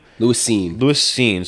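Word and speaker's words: Lewis Cine: The NFL runner-ups, Cincinnati Bengals go with Louis Cine. Is that how you say his name? Is it Cine? – Louis Cine.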